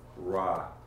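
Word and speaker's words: ra (רע): But the actual definition of the word, if you go to Strong's, is it's the word ra, R-A, and it's pronounced R-A-H, I believe.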